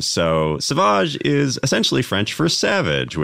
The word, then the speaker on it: Sauvage: The centerpiece is Johnny Depp, and it's pronounced Sauvage.